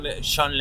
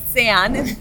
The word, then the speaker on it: Sean: El nombre Sean, que en inglés se pronuncia para mí como John y no. Bueno, nunca lo voy a poder pronunciar bien.